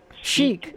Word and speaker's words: chic: I mean, the way it's spelled, it looks like chick, but it's chic.